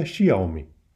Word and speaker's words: Xiaomi: Píše se Xiaomi, čte se Xiaomi.